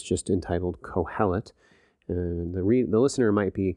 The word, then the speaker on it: Qoheleth: The Hebrew name for the book is Kohelet. It's spelled Q-O-H-E-L-E-T-H, Kohelet, by Graham Ogden.